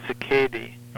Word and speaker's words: cicadae: I don't think there is an accepted pronunciation. We always say in entomology, cicadae. – Okay. – But cicada sounds perfectly fine to me.